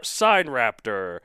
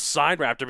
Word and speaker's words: Sinraptor: I know everyone thinks it's Sin Raptor, but it's actually pronounced Sign Raptor because it's like Sinornithosaurus or Sign. It's the hmm. Chinese way to say it.